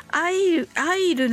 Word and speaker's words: I'll: この I'll なんですけれども I'll とは発音せずにあの I うみたいなねうの口にしないんですけどもさりげなく言っていただけたらと思います。ちっちゃいうだと思ってください。